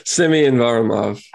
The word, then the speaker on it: Semyon Varlamov: they've shifted away from Semeny Varlamov, their guy who they pay. What's it? Did I butcher the name? – Semyon Varlamov.